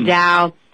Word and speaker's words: Tao: The Tao.